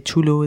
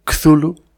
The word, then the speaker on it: Cthulhu: Chulu, Tulu, Cthulhu... Se supone ajá, que es Cthulhu, porque la...